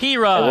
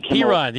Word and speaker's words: Perine: But Perine, like Perine, the all-time leading rusher in the history of Oklahoma football, Dennis.